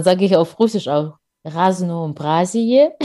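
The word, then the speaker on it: Raznoobrazie (Разнообразие): Also auf Russisch wird es Raznoobrazie, heißt Vielfalt, ausgesprochen.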